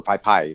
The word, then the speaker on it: Pay-Pay: Before, like long before Pepe, or Pai Pai, if you want to say it right.